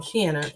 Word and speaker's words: Shianna: Her name is Shianna. I try to remember her name because she said it rhymes with Rihanna, but it's Shianna, and she is a Reiki master.